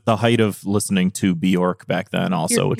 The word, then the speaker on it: Björk: It's pronounced Björk. Everyone says Björk, but it's Björk. It rhymes with jerk.